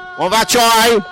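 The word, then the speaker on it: Kung Hei Fat Choi: How do you say Gong Fa Choi? – Gong Fa Choi. Yeah, that's right.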